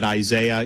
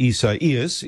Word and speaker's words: Isaias: So, you know, I'm calling it Isaiah. You guys can call it what you want. I know that's not how you pronounce this.